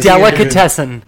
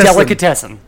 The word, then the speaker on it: delicatessen: It's spelled D E L I, like deli. – Deli? – Okay? – Meat? Hell yeah. – C A T. – Cat. – Okay. E S S E N. – Yes, whatever that is. – Delicatessen. There's no other way to say it.